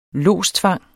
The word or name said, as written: lodstvang